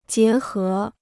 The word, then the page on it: 结合: jié hé